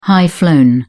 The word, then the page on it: high-flown: British English